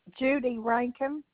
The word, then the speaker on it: Judy Rankin: Judy Rankin.